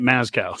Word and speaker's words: Mazzccua: I'm saying it Mazkow, so M-A-Z-Z-C-C-U-A.